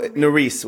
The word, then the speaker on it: Norice: Is that her name? Did I say it wrong? – No, Norice with an S at the end.